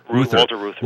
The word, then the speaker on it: Walter Reuther: Walter Reuther.